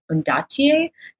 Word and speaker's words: Ondaatje: Ondaatje.